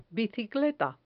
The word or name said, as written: bicicleta